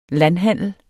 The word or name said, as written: landhandel